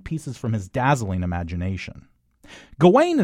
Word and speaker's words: Gawain: This is Lowery's adaptation of the 14th century chivalric romance Sir Gawain and the Green Knight, with Dev Patel playing Gawain, or Gawain if you prefer, or even Garwin, a pronunciation the actor playing King Arthur brought to the table, and that Lowery decided to roll with, nodding to the fact that there are already multiple interpretations of the name and of the original romance itself.